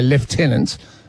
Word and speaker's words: lieutenant: And then just quickly on military ranks, I mean, in the Australian Army, they still say lieutenant, even though the word is spelled lieutenant.